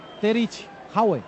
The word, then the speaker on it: Terje Auge: No, te decía lo de noruego porque hay un árbitro noruego que se llama Terje Auge, se escribe, y me dijeron que en noruego se pronunciaba Terich Haue.